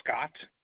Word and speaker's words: Scott: My last name is Scott, S-C-O-T-T.